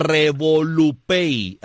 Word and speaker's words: RevoluPay: RevoluPay, así mismo como se pronuncia. O sea, RevoluPay, así de uh-huh, pay de pago, RevoluPay.